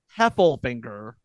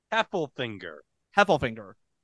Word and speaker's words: Heffelfinger: I've been saying Heflinger all episode long. It is Heffelfinger. – Heffelfinger.